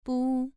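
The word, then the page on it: bu: tone 2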